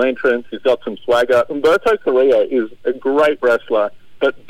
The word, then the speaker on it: Humberto Carrillo: I honestly, Humberto Carrillo, I can't say his name. Help me out here, Simon. – Humberto Carrillo.